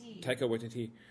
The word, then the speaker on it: Taika Waititi: Speaking of comedy, since Kathleen Kennedy's put out the open call to I can never pronounce his name correctly. Is it Tiki, the guy who directed Thor Ragnarok? – Oh, yeah. Taika Waititi.